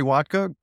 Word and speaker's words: Wodka: Well, my name's Jerry Wodka, and it's pronounced just how you think, vodka with a W.